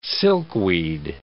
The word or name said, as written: silkweed